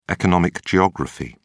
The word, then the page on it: economic geography: British English